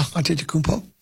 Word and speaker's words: Antetokounmpo: So Antetokounmpo. Am I saying it right? – Yeah. – Antetokounmpo.